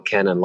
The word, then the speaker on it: Kannan: And then IMDb, you could just look me up, Alex Cannon, K-A-N-N-A-N. That's my last name. That's my law firm name as well, Cannon Law Firm.